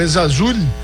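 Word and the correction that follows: Ezzalzouli: Essa é a pronúncia correta, gente? Eu sempre me pego essas perguntas. – Ezazuli, não? – É isso? Ezazuli. Isso, obrigado pela pronúncia perfeita do jogador marroquino, tá, Prat?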